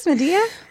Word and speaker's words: Madia: How do you spell it? Spell it to me. – M-A-D-I-A. – Madea. I would say Madea.